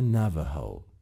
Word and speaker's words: Navajo: اینم که تصویرش اینجا هست، تلفظ میشه Navajo.